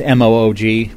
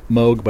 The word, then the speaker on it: Moog: That's M O O G, which is apparently pronounced m- Moog, but a lot of people say Moog. I always thought it was Moog before I started hearing people talk about it, and it's Moog.